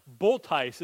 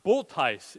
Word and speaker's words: Bulthuis: I've used this example before, but my wife, Kirsten, was born into the name, it was spelled B-U-L-T-H-U-I-S, and nobody could ever pronounce it right. So it got mispronounced all the time. Boltice is how you pronounced it, right? That was what she was born into, and even though that wasn't the name that she desired, she couldn't change it, right? She was just born into it. Boltijs is how it was said, and pronounced correctly by some other Dutch people that lived around her, but most other people did not pronounce it that way.